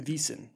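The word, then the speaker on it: Wiesn: They actually call it, I'm going to try to pronounce this properly, Wiesen. – Wiesen. – Wiesen, which is the German word for meadow.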